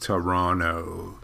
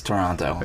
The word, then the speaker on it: Toronto: The second T is not pronounced, so it's just Toronto.